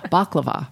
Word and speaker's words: baklava: Baklava, right? That's yes. – How you say it.